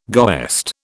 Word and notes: goest: British English

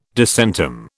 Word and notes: disentomb: British English

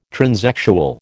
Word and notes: transexual: American English

